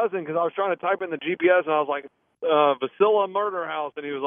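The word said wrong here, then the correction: Villisca: like, you're never going to find that because it's called Velisca.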